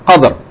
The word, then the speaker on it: Qadr: Qadr.